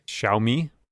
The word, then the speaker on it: Xiaomi: X-I-A-O-M-I? – Xiaomi? – Sure. Xiaomi recently announced their Mi Air Charge technology, or their Mi Air Charge technology. E-Man, can you send me words I can read? But Xiaomi announced their Mi space Air Charge technology, which is able to wirelessly charge devices a few meters away at 5 watts.